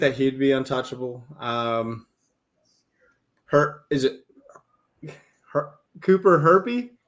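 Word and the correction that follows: Hjerpe: Jerpy. – Chirpy? – Jerpy. The H Jerpy?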